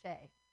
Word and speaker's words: Che: C-H-E. – Cool. But it's pronounced like Shay, so that's good.